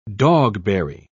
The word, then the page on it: dogberry: British English